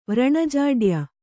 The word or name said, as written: ವ್ರಣಜಾಡ್ಯ